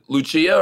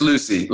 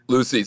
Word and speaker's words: Lucy: Do you pronounce it Lucia, or how do you pronounce it? – It's just Lucy. Like Luciana is her name, but she goes by Lucy. – Lucy.